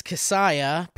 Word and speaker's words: Kasaya: My name is Kasaya, pronounced like Messiah with a K, no relation.